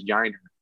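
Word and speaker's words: Yainer: Yeah, another guy who is uh, who's been on fire in, Double A right now, uh, Jiner Diaz, and I say it that way because I went down to Corpus Christi. I found out the, the correct way to pronounce it is Jiner, Jiner, not not with like the hard Y sound.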